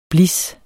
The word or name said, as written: blis